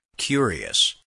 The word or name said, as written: curious